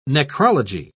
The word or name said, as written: necrology